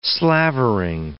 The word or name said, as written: slavering